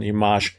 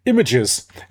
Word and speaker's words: Images: Den Titel habe ich gerade französisch ausgesprochen, Image, aber Oliver Nelson dürfte wahrscheinlich Images gemeint haben von der CD von Jeff Lederer, There's a Yearning.